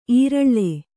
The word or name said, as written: ಈರಳ್ಳೆ